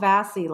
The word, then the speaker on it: Vasey: Am I pronouncing it correct? Because I think I called you Paul Vasey last time. Yes. No, you pronounced it correct this time. Vasey.